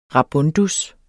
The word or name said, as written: rabundus